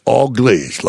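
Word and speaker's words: Auglaize: It's All Glaze, like an all glazed donut.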